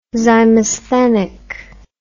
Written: zymosthenic

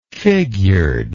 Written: figuredly

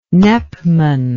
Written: nepman

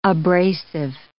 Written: abrasive

